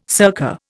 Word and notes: circa: Indian

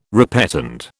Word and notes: repetend: British English

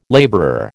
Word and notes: labourer: American English

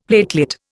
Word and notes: platelet: Indian

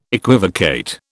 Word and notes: equivocate: British English

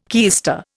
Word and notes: keister: Indian

